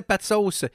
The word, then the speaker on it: Pat Sauce: Oh, c'était Pat Sauce et son excellente chanson Jésus. Une chanson où il faut se concentrer pour bien prononcer son nom. Pat comme dans Patrick. Sauce. Rigueur!